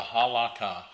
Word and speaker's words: Halakha: H-A-L-A-K-H-A-H. H-A-L-A-K-A-H. The Halakha.